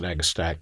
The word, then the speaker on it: agastache: Something I got from you that I just love, agastache, or you pronounce it differently, I think. – I pronounce it agastache. I feel that the Latin CH should be pronounced K.